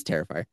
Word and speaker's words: Terrifier: It's Terrifier.